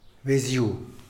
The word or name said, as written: beziu